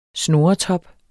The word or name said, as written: snurretop